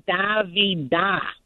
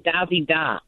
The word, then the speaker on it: Davida: The accent is on the last A, Davida. The accent is Davida, because I have a cousin by that name. The accent is on the last A, not the first A.